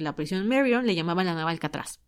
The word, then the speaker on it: Marion: Bueno, entonces, después de este escape, a los 6 meses deciden cerrar por fin Alcatraz y abren la prisión de Marion. O sea, yo le digo Marion porque así se dice en español, pero la pronunciación correcta debería ser Marion.